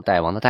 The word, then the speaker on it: 大王: 女真人呃，金国人也把这个戴庆作为封号，直到一六三六年，皇太极把戴庆作为国号之后，戴庆再也不能作为封号使用了啊，千万不能叫成大清啊，那个读大王的代。